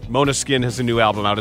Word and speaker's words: Måneskin: Have you heard of the Italian rock band pronounced Måneskin? It's spelled M-A-N-E-S-K-I-N.